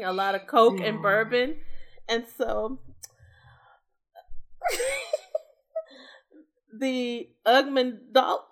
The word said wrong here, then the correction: amygdala: I don't know how to say it. It's a part of the brain, a part of the limbic system. – The amygdala? – Sure. The amygdala.